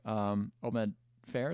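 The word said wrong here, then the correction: Oded Fehr: is that how you say his name? – Oded Fair.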